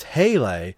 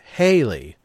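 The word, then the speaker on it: Halley: properly pronounced as Haley, rhymed with valet, as commonly mispronounced as Haley, a comet which orbits the sun near Earth about every 75 years.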